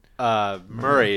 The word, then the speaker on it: Moray: You go through Banff, you go through kind of all these towns, and is it Moray or... – Murray is Murray? How the locals pronounce it. It's spelled more like a Moray eel.